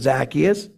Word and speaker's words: Zacchaeus: And when Jesus came to the place, he looked up and said, Zacchaeus, or if you pronounce it Zacchaeus, that's fine, hurry and come down, for I must stay at your house today.